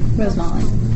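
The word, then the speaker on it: rosemaling: Same cultural community, both Norwegian descendant, both already skilled in rose mailing. Am I saying that right? – Rose mauling. – Rose mauling. – Yeah, but basically.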